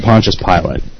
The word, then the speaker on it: Pontius Pilate: I've only ever heard it Pontius Pilate. And, let's see. Oh, Pontius Pilate is, it says pronounced... The Wikipedia article says it's pronounced Pontius... Actually, Pontius Pilate.